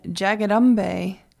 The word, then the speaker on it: Jagadambe: And then Jagadambe is actually two different words put together. So it's Jagat, which is J-A-G-A-T, and then Ambe, which is A-M-B-E. And so put together, it's written out as it's pronounced Jagadambe, but it's these two words.